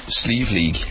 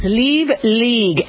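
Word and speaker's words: Slieve League: how do you pronounce it again? – Sleeve League. – Sleeve League, and sleeve means mountain.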